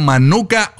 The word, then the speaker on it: Manuka: Porque vengo hablando de, ahorita es Hanukkah Honey. Es la Manuka Honey. Es que lo dije mal.